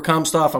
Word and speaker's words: Komsthoeft: Cooper Komsthoeft, I'm saying that right? – Yeah. – Make sure I'm hitting that right. We're bringing Cooper Komsthoeft on.